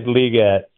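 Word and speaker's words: Legette: I noticed you went Leggett Is there an official pronunciation we need to be aware of for this? – I think it is Liget.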